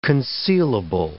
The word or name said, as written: concealable